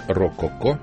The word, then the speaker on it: Рококо: Я просто напомню, что мы слушали сегодня фрагменты из альбома московского коллектива, который называется «Рококо», ударение на «рок», а не на музыку эпохи рококо.